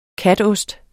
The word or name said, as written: katost